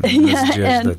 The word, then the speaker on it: Nadezhda: No, I like your pronunciation. Nadezhda, that Russian is beautiful.